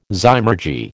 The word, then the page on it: zymurgy: American English